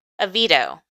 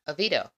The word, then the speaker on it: Oviedo: Oviedo. Oviedo.